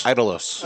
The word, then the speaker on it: Idolos: So early thoughts here on Atabey Idols. – All right. – Idols? – Idolos. – Idolos. – Which means idols. – It's Idolos is how it's pronounced.